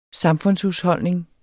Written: samfundshusholdning

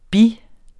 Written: bis

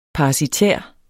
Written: parasitær

